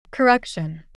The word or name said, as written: correction